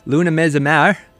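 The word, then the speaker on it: Luna Mezz'o Mare: We had Lazy Mary, Luna Mezamar, that's how you pronounce it.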